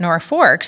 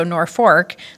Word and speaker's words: Norfolk: So they wrote Norfolk, and the people back east thought, they must mean Norfolk, like Norfolk, Virginia, changed the name. Well, people still continued to pronounce it Norfolk. So my parents are from there. So I grew up only knowing it by sound, so Norfolk.